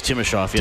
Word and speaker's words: Timashov: Is that, am I saying it right? – Oh, I think it's Timashov, yeah.